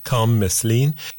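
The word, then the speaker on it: Khaam Meslien: His first name is spelled K-H-A-M and then M-E-S-L-I-E-N, Kam Meslin.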